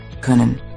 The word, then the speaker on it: können: You'll find this letter in a lot of German words, so let's look at how it's pronounced in a very common verb. This verb means to be able to, like when you can do something. – Ö. Können. Sie können.